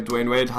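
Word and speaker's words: Dwyane Wade: Dwayne Wade, hast du richtig gesagt, ist immer noch ein Superstar.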